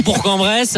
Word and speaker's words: Bourg-en-Bresse: seulement pour Bourg, parce qu'on prononce bien là, la fin euh, pour Bourg-en-Bresse.